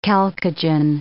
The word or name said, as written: chalcogen